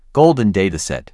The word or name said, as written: golden dataset